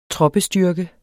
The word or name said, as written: troppestyrke